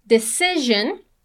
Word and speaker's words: decision: Pay attention to the pronunciation of decision. The second S has a Z sound. Decision, just the two of us can make.